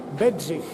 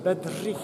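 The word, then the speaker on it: Bedřich: How do you pronounce his name? – Bedjich. Bedjich. Bedjich Smetana. It's a little bit difficult. R. – Yeah. – Small accent. – You yeah. put the tongue behind the teeth.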